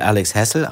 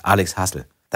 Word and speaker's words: Alex Hassel: Ich lese natürlich Alex Hessel, aber dann heißt es plötzlich, der heißt gar nicht Alex Hessel. Der heißt Alex Hassel.